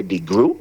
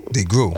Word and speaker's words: DeGruy: And Dr. Joy DeGray will be special speaker. – Let me give the pronunciation of that. It's Joy DeGruy. – DeGruy.